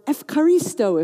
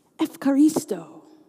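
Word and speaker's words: Ephcharisto (ευχαριστώ): "Eucharist," or "Ephcharisto" if you want to blend in with the other Greeks. Ephcharisto.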